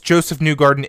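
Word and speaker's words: Josef Newgarden: pronounced Joseph Newgarden, Indianapolis 500 champion. That's how you pronounce his name.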